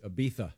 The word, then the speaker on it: Ibiza: It's Ibiza. – It's Ibiza. – Come on with that. – The Z is pronounced TH.